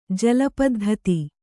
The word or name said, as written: ಜಲಪದ್ಧತಿ